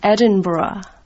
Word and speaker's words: Edinburgh: How do you pronounce that? – We say Edinburgh. – You don't put the G-H on it. – That's right.